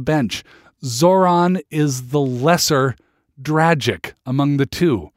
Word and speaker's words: Dragic: And I'm told that it's pronounced Dragic and not Dragic. Dragic rhymes with tragic or magic.